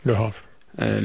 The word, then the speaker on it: Le Havre: Yeni bu sezon Le Havre mı okunuyor takım ismi? – Le Havre.